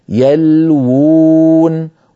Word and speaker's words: يلوون: كتبوها بواو واحدة وهم ينطقونها بواوين، يلوون.